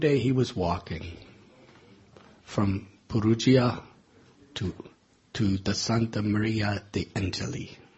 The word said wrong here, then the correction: Santa Maria degli Angeli: Did I say it correctly? Huh? Santa Maria degli Angeli. Santa Maria degli Angeli. Is that correct?